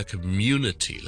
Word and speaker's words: community: 他觉得这个字很难读，尤其是是我们讲得快的时候， community, 一个社区 ,C-O-M-M-U-N-I-T, why。第一件事要特别留意嘅咧，community 咧就系个重音喺 the second syllable， mu mu mu，而我哋要读好呢个音咧，先读好 u y o u 嘅 u u u u u u u u 咗先，跟住跟埋后面嗰啲音啦，听住 unity unity unity，跟住前面加多个 m 音咧，变咗 munity munity munity。